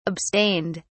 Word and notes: abstained: British English

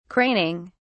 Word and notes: craning: British English

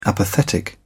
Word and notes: apathetic: British English

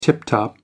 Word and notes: tip-top: American English

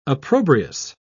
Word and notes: opprobrious: British English

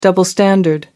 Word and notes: double standard: American English

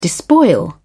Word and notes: despoil: British English

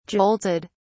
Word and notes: jolted: British English

